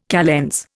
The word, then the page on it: calends: Indian